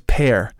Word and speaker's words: Père: And in French, that's Père. So it's close in pronunciation to the fruit, you know, pear, like as in I eat a pear or I have a pair of socks.